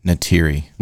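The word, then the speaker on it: Neytiri: And then raises a family with Nytira. So now he is fully... – Nytiri. – Nytiri, okay.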